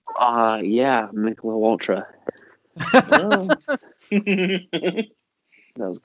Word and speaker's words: Michelob: Plus, it's pronounced Michelob Ultra. – Michelob. Michelob. It's Michelob.